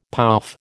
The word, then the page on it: pouf: American English